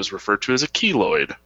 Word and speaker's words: keloid: Keloid.